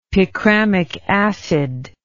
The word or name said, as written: picramic acid